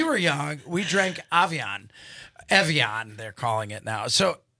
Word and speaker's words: Evian: Anastasia, do you remember Avion? Have you seen Avion? – You mean Evian? – Evian.